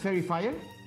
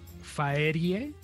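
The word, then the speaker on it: Faerie Fire: ¿Es Fairy Fire? ¿Ah? No lo encuentro. Faerie. Faerie ah. Fire. Ah, es que se escribe raro.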